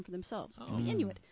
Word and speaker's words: Inuit: Yes, they've done some studies recently that show in the frozen Arctic, the native peoples who live in this region, the Inuit. – That's how you pronounce it, Inuit. – Inuit. – Not Inui or...